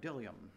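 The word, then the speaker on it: bdellium: So you see that word dillium, the B is silent, just so you know. I checked that out. I wonder, how do you say dillium? You don't pronounce the B.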